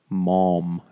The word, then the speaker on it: Maugham: Maugham is how it's pronounced, apparently.